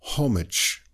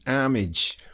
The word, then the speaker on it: homage: I believe the British pronounce the H, so they would say homage. And in America, they drop the H. It depends where you are. If you're in New York, it might be homage.